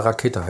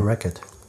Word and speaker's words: Rackete: Racket. – Sagt man jetzt, weil es ein CK ist. R-A-C-K-E-T-E. – -E. Rakete. – ja, Rakett.